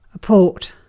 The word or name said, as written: port